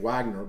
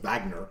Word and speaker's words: Wagner: I would pronounce his name Wagner, but because he's from Germany, they pronounce it Wagner.